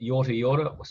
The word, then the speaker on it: Yorta Yorta: Yep, Yorta Yorta, spelled Y-O-R-T-A, and you say it twice.